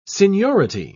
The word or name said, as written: seniority